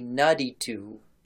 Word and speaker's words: naditum: And so that you can Google this on your own, that is spelled N-A-D-I-T-U-M. But that M on the end is silent because Akkadian apparently is one of those languages that has final consonants that you don't pronounce unless it's like conjugated into something else.